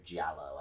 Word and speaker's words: Giallo: Um, it is an Italian uh, Gallo film. Or Giallo. I always get the um, pronunciation wrong on that.